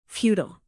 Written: futile

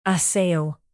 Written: assail

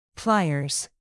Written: pliers

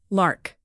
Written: lark

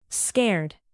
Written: scared

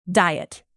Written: diet

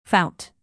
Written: fount

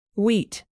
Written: wheat

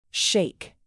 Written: sheik

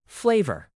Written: flavor

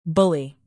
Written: bully

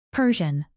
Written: persian cat

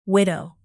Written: widow

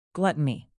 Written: gluttony

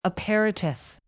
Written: aperitif

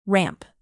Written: ramp

